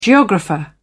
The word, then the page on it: geographer: British English